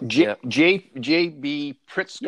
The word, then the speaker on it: J.B. Pritzker: J.B. Pritzker, yes. Am I saying that right? J.B. Pritzker is your governor, and I've seen him a couple of times on CNN. – Good work.